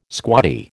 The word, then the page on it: squaddie: American English